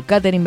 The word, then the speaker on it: Katherine: Nombre y apellido, Katherine Velázquez. ¿Cómo se escribe Katherine? Con K, sin H, sin E, como suena.